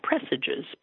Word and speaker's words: presages: So anyway, all of this uh, presages, is that how it's pronounced? yeah, presages, presages, presages a uh, nightmare in November.